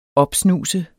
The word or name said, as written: opsnuse